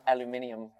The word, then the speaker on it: aluminium: Just they also say aluminium. – Aluminium, which I prefer their pronunciation.